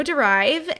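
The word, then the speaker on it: Dérive: So that's when I came up with my business, Studio Derive. And for those of you who have never heard of Studio Derive, on Derive it has an asterisk over the E, which is technically pronounced derive,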